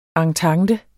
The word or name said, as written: entente